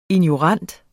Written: ignorant

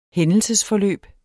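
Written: HÆNDELSESFORLØB

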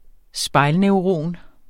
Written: spejlneuron

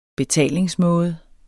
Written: betalingsmåde

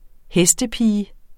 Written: HESTEPIGE

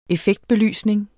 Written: effektbelysning

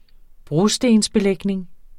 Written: brostensbelægning

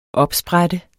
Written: opsprætte

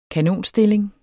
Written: kanonstilling

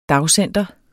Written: dagcenter

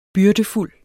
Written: byrdefuld

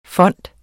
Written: FONDETS

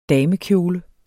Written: damekjole

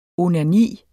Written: onani

